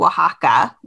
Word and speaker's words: Oaxaca: Every year on December 23rd, the Mexican state of Oaxaca, which, by the way, I am saying correctly, even though it is spelled O-A-X-A-C-A, mm-hmm.